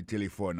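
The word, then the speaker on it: telephone: Téléphone, c'est comme ça, on dit téléphone en anglais. Nous devons répéter encore pour nous, téléphone. N'a remarqué que l'accent tonique l'a tombé sous premier syllabe là.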